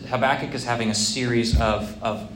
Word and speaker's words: Habakkuk: Joey, how do you pronounce the name? – Habakkuk. – You say Habakkuk? Robert, what do you say? – Rhymes with tobacco. – Right. So I've heard Habakkuk.